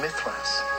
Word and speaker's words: Mithras: And you go underneath and there's a little sanctuary of Mithras.